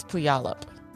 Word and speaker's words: Puyallup: I think it's Puyallup. – Really? Like, you've heard of this place? – I think I have. – Wow, because I think you nailed the pronunciation.